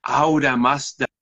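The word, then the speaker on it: Ahura Mazda: que en lenguaje farsi, el lenguaje persa, se dice así. Aura Mazda, Aura Mazda.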